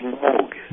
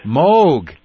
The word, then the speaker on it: Moog: It's Moog. – Moog.